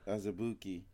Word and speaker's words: Azubuike: Azubuki. – Azubuike.